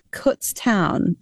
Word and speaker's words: Kutztown: Can you tell me about Kutztown? Is that how you say it? – Yes, you're the only person to actually say that right first time.